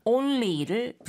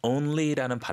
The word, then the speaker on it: only: Cold wash 혹은 cold water only를 붙이게 되면 이것만 가능하다가 되겠죠. – 가끔씩 우리 한국 친구들이 only라는 발음이 조금 힘들어서 oh, 네. – only 이러거든요. – 네. – only가 아니고 only가 맞겠죠.